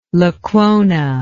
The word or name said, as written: Lecuona